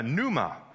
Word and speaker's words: pneuma: In the Greek language, it's a pneuma. The P is silent.